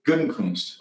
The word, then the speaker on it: Gutekunst: Did you just laugh at my pronunciation? What? Gutekunst? I think it's right. What is it? – Gutekunst. – Okay. Well, you're good.